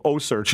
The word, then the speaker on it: OCEARCH: Chris Fisher is with me, founder and expedition leader of OSearch. And by the way, that is spelled O-C-E-A-R-C-H.